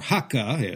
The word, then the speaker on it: Hakka: So right off the bat, these two neighbors, the Bundi, the local Yue people, and these newly arrived guest people, these Kejiaren, or Hakka, as Kejia is pronounced in Cantonese, lived side by side But animosities brewed over the circumstances of the Hakka's sudden and overwhelming presence in these traditionally Yue lands.